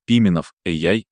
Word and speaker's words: Пименов AI: Пименов AI с ударением на «и» и без лишней паузы внутри AI.